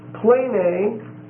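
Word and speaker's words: Plane (πλάνη): The next word is plane. P-L-A-N-E. The long E. Plane. P-L-A-N-E.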